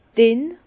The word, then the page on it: dinn: Bas-Rhin; Herrlisheim